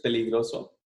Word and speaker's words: peligroso: And I called him, and I'm probably going to mispronounce it, 'cause like I said, I did terrible in Spanish, but, Peligros. Dangerous? Oh, dangerous, peligroso.